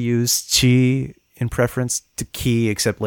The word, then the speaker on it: ki: But the same character in Japanese is pronounced ki, with a hard K sound, just like English.